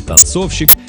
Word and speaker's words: танцовщик: Ударение в слове «танцовщик» точно так же падает на второй слог, как и в слове «танцовщица».